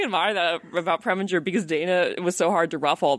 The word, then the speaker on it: Dana: Dana's name properly. He never learned how to say Dana. He would call him Donna.